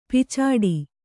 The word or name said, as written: ಪೀಚಾಡಿ